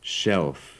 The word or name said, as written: shelf